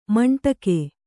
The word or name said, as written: ಮಂಡಗೆ